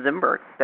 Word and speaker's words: Zinberg: It's Len Zinberg.